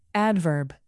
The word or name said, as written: adverb